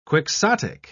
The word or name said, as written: quixotic